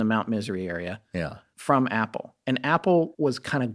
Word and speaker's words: Apol: called himself Apple, A P O L, pronounced Apple.